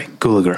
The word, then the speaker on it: Gulager: Clu Gallagher is, sorry, Gulager, I always say his name wrong,